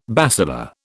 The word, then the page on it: basilar: British English